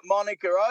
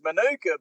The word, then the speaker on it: Manuka: But the funny thing is, I let you go on this rant about Monica Oval, but I actually think it is pronounced Manuka. But who cares? I'm happy to hear you say Monica.